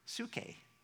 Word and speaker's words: psyche: It sounds like psyche, but it's spelled P-S-Y-C-H-E. It's psych, It's where we get the word psychology, and that has to do with our soul.